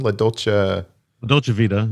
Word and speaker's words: La Dolce Vita: What's the title of that film? – La Dolce Vita. – Yeah, La Dolce Vita. Okay, I said it right.